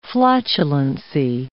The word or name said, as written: flatulency